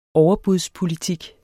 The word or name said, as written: overbudspolitik